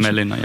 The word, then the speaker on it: Hämeenlinna: Hämeenlinna, yeah.